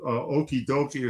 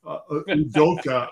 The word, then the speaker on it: Udoka: And I do think the coaching mismatch, Charles Barkley calls him Okie Dokie or something. It's Udoka, I think is how you pronounce it. – Udoka, yeah, something like that.